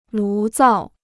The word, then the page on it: 炉灶: lú zào